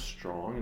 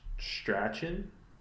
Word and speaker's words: Strachan: And this is confusing because it's pronounced Strong, and I didn't know that until his brother caught a pass recently. Prince Strong, which I used to pronounce as Strachan, Michael Strachan's little brother.